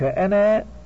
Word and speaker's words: أنا: وكتبوا ألف أنا مع أنها لا تلفظ في درج الكلام، لأنها إذا وقف عليها وقف عليها بالألف.